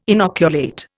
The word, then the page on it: inoculate: Indian